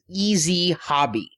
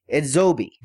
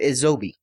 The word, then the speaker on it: EZHOBI: Everybody's pronouncing this company's name as Easy Hobby, because that's how you spell it, is E-Z-H-O-B-I. However, the guys at Yes Anime said it's pronounced Edzobi.